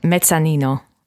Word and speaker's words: mezzanino: Mezzanino.